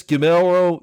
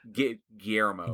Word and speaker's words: Guillermo: And it was directed by the genius Guillermo del Toro. I didn't pronounce it right. – Guillermo. – Guillermo del Toro. – Yeah, there you go.